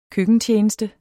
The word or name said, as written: køkkentjeneste